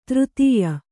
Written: ತೃತಿಯ

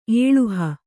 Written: ಏಳುಹ

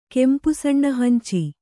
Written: ಕೆಂಪುಸಣ್ಣಹಂಚಿ

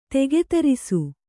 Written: ತೆಗೆತರಿಸು

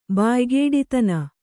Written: ಬಾಯ್ಗೇಡಿತನ

